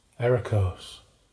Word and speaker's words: Eriko's: So, how do you pronounce Eriko's? – Eriko's. – Eriko's, yeah. I looked up E with an umlaut and how it's supposed to be pronounced, and it's a hard E. So, technically it should be Eriko's E. But that sounds bloody stupid, so I'm not doing it. I'm sticking with Eriko's.